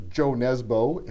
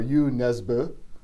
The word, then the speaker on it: Jo Nesbø: I've been speaking with either Joe Nesbo, if you want to use the North American pronunciation, or Jo Nesbø, if you want to use the Norwegian pronunciation, which I probably have hammered severely, but as close as I can come with these lips.